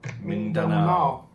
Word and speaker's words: Mindanao: M-I-N-D-A-N-A-O. Mindanao.